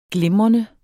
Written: glimrende

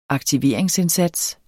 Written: aktiveringsindsats

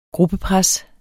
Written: gruppepres

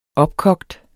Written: opkogt

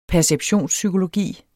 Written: perceptionspsykologi

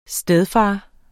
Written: stedfar

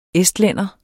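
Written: ESTLÆNDERE

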